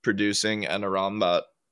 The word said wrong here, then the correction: Amrabat: or Amrabat. Amrabat. Yeah, I was just fixing myself.